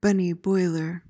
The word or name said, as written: bunny boiler